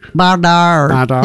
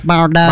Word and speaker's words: Bardot: We just played Bardot. – Bardot. You've got to say it like that, Bardot.